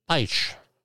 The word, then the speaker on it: Beitsch: Beitsch. I'm pronouncing it the German way.